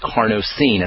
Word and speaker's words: carnosine: That's spelled C-A-R-N-O-S as in Sam, I-N as in Nancy, E, carnosine, as opposed to zinc picolinate.